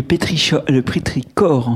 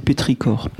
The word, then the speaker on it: pétrichor: D'ailleurs, dans le dernier tirage, vous n'avez pas le dernier tirage, j'ai rajouté la prononciation parce que c'est un CH euh, venu d'une racine grecque, donc c'est le pétricor.